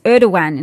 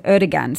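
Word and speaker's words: Erdoğan: It's Erdogan instead of Erdogan, so I will proceed with the corrected pronunciation.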